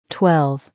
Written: 12

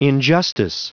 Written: injustice

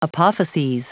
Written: apophyses

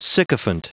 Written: sycophant

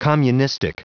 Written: communistic

